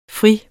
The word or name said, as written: fri